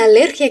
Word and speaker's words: alergia: La primera es que la G seguida de I se dice GI. La segunda es que la I no suena más fuerte que la A en español si no lleva tilde. Es decir, si no hay acento escrito en la I, no hay sonido IA como en alergia en portugués.